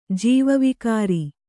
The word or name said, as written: ಜೀವವಿಕಾರಿ